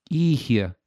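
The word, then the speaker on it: Oíche: It's off of an album that is an Irish word that means night. And it's pronounced Eihe.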